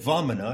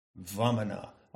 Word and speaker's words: Vamana: So I take the pronunciation of Vamana, where the V and the W, the V is close to a W, you catch that,